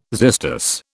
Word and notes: xystus: British English

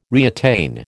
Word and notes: reattain: American English

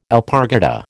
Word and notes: alpargata: American English

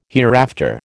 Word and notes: hereafter: American English

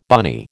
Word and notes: bunny: American English

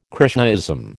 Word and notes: Krishnaism: American English

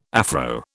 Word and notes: Afro: British English

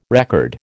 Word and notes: record: American English

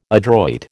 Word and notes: adroit: American English

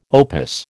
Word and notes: opus: American English

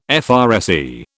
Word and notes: FRSE: British English

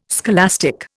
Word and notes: scholastic: Indian

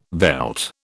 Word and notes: veld: British English

